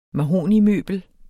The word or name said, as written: mahognimøbel